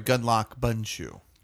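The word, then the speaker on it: Gundlach Bundschu: And this winery is Gunlatch Bunshu. Yes, you can't pronounce it. – Gunlock. – Yeah. I'm sorry, Gunlock Bunshu. What did I say? – Watch.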